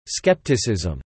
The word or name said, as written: skepticism